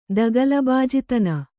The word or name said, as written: ದಗಲಬಾಜಿತನ